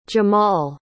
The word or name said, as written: jamall